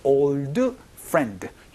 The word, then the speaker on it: old friend: 여기서 old friend 이렇게 발음하지 않고 old friend, 디소리가 잘 나지 않기 때문에 주의를 해주시면 좋겠습니다.